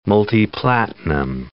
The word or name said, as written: multiplatinum